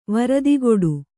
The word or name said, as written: ವ್ರತಗೆಡು